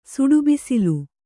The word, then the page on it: ಸುಡುಬಿಸಿಲು: Kannada